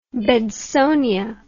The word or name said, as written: bedsonia